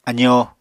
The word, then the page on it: azóo: apatani